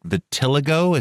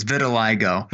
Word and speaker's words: vitiligo: vitiligo? Is that how you pronounce this discoloration on the arm? – Close. It's vitiligo.